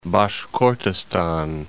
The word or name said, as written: Bashkortostan